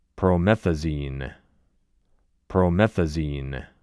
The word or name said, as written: Promethazine